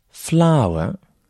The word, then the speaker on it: flour: Quatrième mot, flower » signifie la farine. Flower. La prononciation est exactement la même que pour une fleur en anglais, a flower.